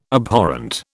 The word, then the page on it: Abhorrent: British English